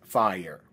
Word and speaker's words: FHIR: called FHIR, which is usually pronounced FHIR.